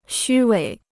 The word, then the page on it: 虚伪: xū wěi